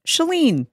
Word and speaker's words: Chalene: My name is Shalene. This is The Shalene Show, and my name is pronounced Shalene.